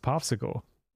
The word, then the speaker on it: PopSQL: So I think this is actually pronounced Popsicle.